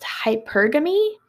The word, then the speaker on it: hypergamy: I think this one's pronounced hypergamy. It's spelled H-Y-P-E-R-G-A-M-Y.